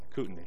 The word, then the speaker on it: Kootenai: The work of the ordained men here in this church, how do you say the name of this church? Is it Kootenai? Is that close? That's close. What is the real way of saying it? Kootenai. Kootenai. Okay.